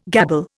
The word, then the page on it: gabble: Indian